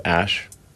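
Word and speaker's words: Æ: The AE is like... pronounced Ash.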